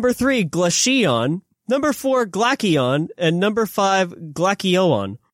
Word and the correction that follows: Glaceon: No, it's Glaceon. That's your silent.